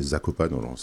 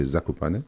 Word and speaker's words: Zakopane: On connaît Zakopane à la prononciation française, mais en polonais c'est Zakopane.